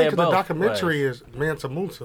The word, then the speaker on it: Mansa Musa: And it's pronounced Mansa Musa.